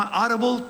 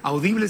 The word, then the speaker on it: Audible: Oigan, pues mañana 4 de junio se estrena en la plataforma Audible, pronunciado en inglés, Audible si lo quieren pronunciar en español.